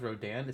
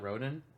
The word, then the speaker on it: Rodan: Yeah, I found it's pronounced Rodan. It's not Rodan.